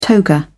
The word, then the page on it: toga: British English